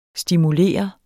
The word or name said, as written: stimulere